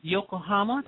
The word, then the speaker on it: Yokohama: Well, I want to play one more song, Nicholas, before we let you go, and it's track number 6, Yokohama.